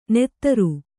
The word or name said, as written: ನೆತ್ತರು